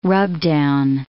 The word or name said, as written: rubdown